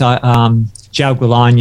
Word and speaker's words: Jiaogulan: Jiaogulan. – Jiao. Second word, gu, G-U. And then third word, lan, L-A-N. – L-A-N.